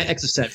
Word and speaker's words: Exocet: I think it's SSX missile. – Exocet from France.